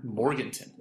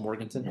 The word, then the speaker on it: Morganton: Is it spelled like Morgantown, but it's called Morganton? – Yeah. – Okay. – That's how I say it. That's how people in North Carolina say it.